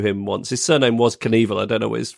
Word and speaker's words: Knievel: It's weird that the K is pronounced in Knievel too. – Yeah. – You don't say anyway, knife.